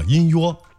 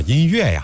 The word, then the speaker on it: 音乐: 后来呢，学到了正宗的河南话，它其实发音叫做音哟。再后来我才真正知道了它的发音，原来啊是叫音乐呀。